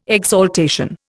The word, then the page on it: exaltation: Indian